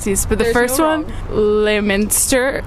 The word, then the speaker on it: Leominster: So we would say Leominster.